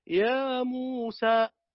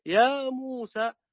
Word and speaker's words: يا موسى: قلت شيخ الحبيب يعني ابن الجزر رحمه الله تعالى يقول واللفظ في نظيره كمثله، يعني كما تنطق يا موسى الياء فيها حركتان والميم فيها حركتان والسين حركتين، يا موسى فهي تماما كما تنطق بقوله تعالى ولا الضالين، فلا تزيد أكثر من هذا.